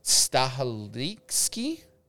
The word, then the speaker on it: Stahelski: First bit of news, Ghost of Tsushima movie director Chad, and I'm sorry if I mispronounce this, Stahelski, that's how you pronounce it, wants to have the film in full Japanese.